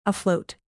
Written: afloat

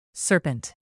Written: serpent

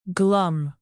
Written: glum

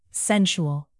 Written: sensual

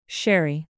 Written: sherry

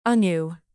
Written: anew

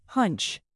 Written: hunch

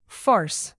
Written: farce

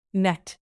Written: met

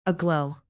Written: aglow

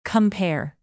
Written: compare

